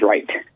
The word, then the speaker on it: Wright: Last name is Wright, W-R-I-G-H-T. So Nicholas is spelled N-I-C-H-O-L-A-S, and Anthony, A-N-T-H-O-N-Y, and of course Wright, W-R-I-G-H-T.